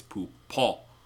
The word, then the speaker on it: Poupon: You keep saying the non-existent N in Grey Poupon. It's not Grey Poupon. It's not a coupon. Or a coupon. – Well, I'm also sorry. I have dyslexia, so I read it phonetically. – I know. That's why I'm sorry. – That's why me, the wannabe voice actor, is helping you out here. It's Poupon.